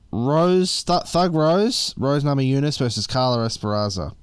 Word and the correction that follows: Esparza: or Carla Es Carla Esparza. Sorry, I mispronounced that name, for the strawweight women's title.